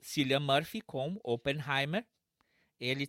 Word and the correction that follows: Cillian: Deixa eu te corrigir. É Killian. – Killian, Killian Murphy. perdão.